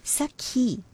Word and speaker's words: sakhi: The first syllable is a soft sa, like in sun. Ala cheptam kada, adi sa, sa. The second syllable is a very stressed syllable, and you can pronounce it like this, ki, ki. So when you say ki, there's an aspirated ka, ka there, and then you convert it into ki. Sakhi, sakhi.